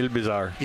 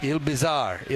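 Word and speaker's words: Île-Bizard: You're going to tell me that I'm wrong in my French-Canadian pronunciation of that? – Yeah, Ile-Bizard. – Ile-Bizard, Ile-Bizard, Quebec.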